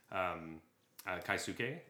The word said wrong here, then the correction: Keisuke Sato: and that's my bad. Uh, so it's Keisuke Sato.